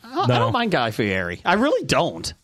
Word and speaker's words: Fieri: Ferrari? How do you say his name? – It's Fieri.